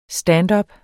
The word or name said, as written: standup